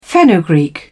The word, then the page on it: fenugreek: British English